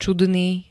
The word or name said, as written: čudný